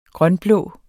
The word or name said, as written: grønblå